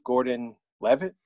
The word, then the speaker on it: Gordon-Levitt: For you, I picked Joseph Gordon-Levitt.